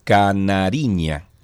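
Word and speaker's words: Canarinha: Canariña por como caipiriña. Canariña, sí. Como canariña.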